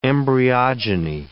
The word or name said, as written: embryogeny